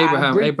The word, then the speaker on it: Abraham: Question though, do you think that Tammy Abraham, I hope I'm saying his name properly, because I want to say Abraham because of the Bible, but Abraham. Okay.